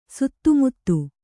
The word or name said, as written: ಸಿತಮತಿ